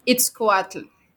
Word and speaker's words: Itzcoatl: We cannot talk about all of them, but in chapter two, you tell us about the the story of Itzcoatl, if I'm pronouncing that right.